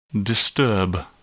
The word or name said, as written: disturb